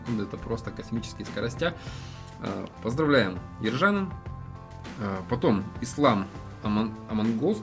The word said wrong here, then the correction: Аманкос: да? – Аманкос.